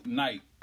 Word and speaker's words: knight: K, knight. Like a knight, like a fighting, like a shining armor. Nigga, as a kid, I had so much trouble learning. – Knife. It's the silent letters.